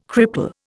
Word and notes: cripple: Indian

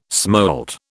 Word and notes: smolt: British English

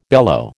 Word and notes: bellow: American English

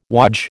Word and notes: wodge: American English

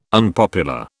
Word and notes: unpopular: British English

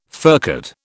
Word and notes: furcate: British English

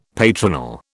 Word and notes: patronal: British English

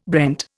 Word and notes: brent: Indian

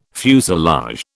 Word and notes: fuselage: British English

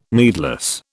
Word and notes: needless: British English

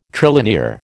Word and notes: trilinear: American English

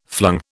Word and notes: flung: British English

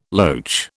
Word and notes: loach: British English

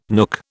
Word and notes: nook: British English

